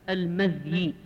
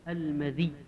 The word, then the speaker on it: المذي: أما قوله باب في المذي، أما المشهور في هذه الكلمة بنطقها هكذا المذي بالتخفيف فتح الميم وسكون الذال وتخفيف الياء، هذا هو المشهور المذي، المذي. وفيه لغة أخرى، وفيه لغة أخرى وهي فتح الميم وكسر الذال وتشديد الياء، المذي، المذي.